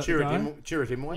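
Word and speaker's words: Chirrut Îmwe: Chewrat, am I pronouncing him correctly, from Rogue One? – I am one with the Force and the Force is with me. Is that Chirrutimoy? – Yes.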